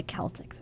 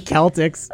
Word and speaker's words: Celtics: I think the Celtics would be better. – I think so, too. – Because that's how it's spelled. – It's how it's pronounced. – Like pronounced. You say Celtic music. – Yeah, you wouldn't say Celtic music.